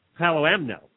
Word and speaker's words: Palomino: It's a Palomano horse. A Palomano. – Palomano. – Palomino. You're right. Palomino. I'm sorry. I mispronounced that name of that horse.